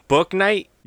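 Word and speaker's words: Bouknight: Book Knight. – Yes, I believe that's how you say it.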